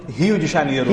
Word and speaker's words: Rio de Janeiro: Rio de Janeiro. – Rio, because the R is with the H.